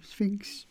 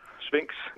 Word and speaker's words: Sphinx: Du er forfatter, og du er aktuel med romanen Sphinx. – Ja. – Var det rigtigt udtalt? – Det er fuldstændig rigtigt, ja. Sphinx, ligesom statuen nede ved pyramiderne.